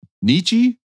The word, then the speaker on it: Nietzsche: Nietzsche? I've never known how to say Nietzsche. – It. Nietzsche? – Yeah, that's so, fine. – A lot of letters for just Nietzsche.